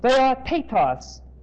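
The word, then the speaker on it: theotetos (θεότητος): It is theotetos. Theotetos. T-H-E-O-T-E-T-O-S.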